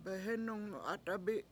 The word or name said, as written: behEnung 7At$abi7